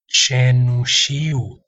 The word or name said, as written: cenusiu